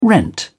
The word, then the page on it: rent: British English